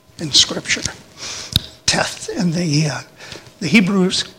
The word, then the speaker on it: Teth: And this one begins with the letter Teth, T-E-T-H. And actually, it's just the T-E-T is what's pronounced in it.